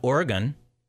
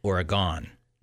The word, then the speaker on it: Oregon: It's not Oregon, like the state, it's Oregon. Now, it's spelled the same.